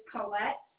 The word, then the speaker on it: Colette: My first name is Colette, C-O-L-E-T-T-E.